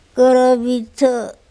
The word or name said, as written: korobitHo